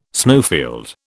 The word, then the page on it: snowfield: British English